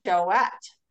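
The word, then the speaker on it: Jouët: They taught me that if there are two dots over the E, that you pronounce the T. – Two dots over the E, you pronounce the T. – Choette.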